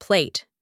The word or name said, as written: plate